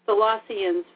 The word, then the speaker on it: Colossians: Colossians, is that how you pronounce Colossians.